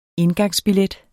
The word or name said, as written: indgangsbillet